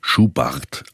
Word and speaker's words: Schubert: Zu Franz Schubert, der im Osttiroler Idiom Schubart ausgesprochen wird, hat Franui eine besondere Beziehung.